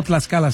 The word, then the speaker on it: Tlaxcala: ¿Es Tlaxcala? – O Tlaxcala, sí.